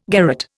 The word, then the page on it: garrotte: Indian